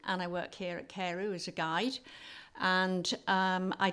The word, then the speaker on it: Carew: Carew is how we say it in English. – Yeah, okay. In Gymraeg. – Carew. – So Castell Carew.